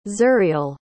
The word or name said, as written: zuriel